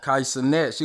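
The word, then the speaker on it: Kai Cenat: Kai Sinet.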